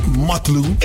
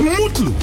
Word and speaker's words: Mutlu: It's not Mutlu, it's Mutlu. That's Mutlu Battery.